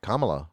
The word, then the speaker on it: Kamala: You said it right, Kamala. – Kamala. – No, it's Kamala.